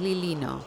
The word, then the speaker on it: Lilino: Porque es un apellido italiano y la doble L se pronuncia una L alargada, por eso es Lilino.